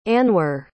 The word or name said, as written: anwer